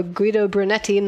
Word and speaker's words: Guido Brunetti: It's the 27th Commissario Guido Brunetti novel. Am I saying this right, Mark? – You are fantastic.